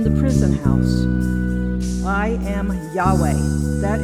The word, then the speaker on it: Yahweh: In Hebrew, over 6,000 times, we see his name is not his title of Lord, but four letters, YHWH, usually pronounced as Yahweh.